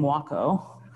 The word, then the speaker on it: Wako: You can't really see very well the two Chinese characters or Japanese characters that make up the name Wako. It rhymes with taco. That's how to pronounce it.